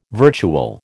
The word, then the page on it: virtual: American English